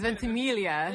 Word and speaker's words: Ventimiglia: It's Ventimiglia. – Ventimiglia, yes. – Like familia. – I was going to stutter again. – That's what an Italian person told me, because I did pronounce it and I butchered it, and she literally was like, what are you saying? It's familia. It's like that.